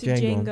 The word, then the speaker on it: Django: It's Django. Django, but D with. It starts with a D. The D is silent.